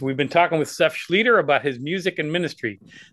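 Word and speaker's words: Schlueter: Well, and in case you want to spell his last name, it's pronounced Seth Schlieder, but it's S-C-H-L-U-E-T-E-R.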